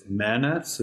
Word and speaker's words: MANETs: Um, the title of your talk is Manets.